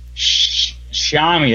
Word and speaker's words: Xiaomi: Think of Xiaomi, pronounce the first word as if it were shower. Show what? The mystery ends here. According to the Xiaomi? – Xiaomi, that's right.